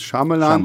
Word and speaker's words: Shyamalan: Shyamalan, Shyamalan, ihr dürft ihn aussprechen, wie ihr wollt. Ich glaube, die richtige Aussprache ist Shyamalan.